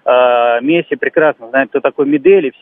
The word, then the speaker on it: Медель: Вот разбирая тот момент, Петр, там, конечно, объективности ради стоит сказать о том, что вот когда они сблизились, Медель все-таки Медель, ударение, спасибо.